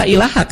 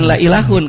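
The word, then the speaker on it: la ilaha (لا إله): Jadi kalau kan dibacanya la ilaha kan, bukan la ilahun kan?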